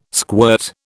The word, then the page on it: squirt: British English